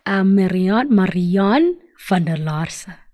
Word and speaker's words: Marianne van der Laarse: You're speaking to Lerato Mokati, and I have a special guest in the house tonight, Marianne van der Laarse.